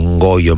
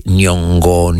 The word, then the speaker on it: Nyong'o: Lupita, io dico Ngoyo, ma in realtà poi è difficile pronunciare, che sarebbe Nyong'o.